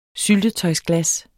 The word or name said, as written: syltetøjsglas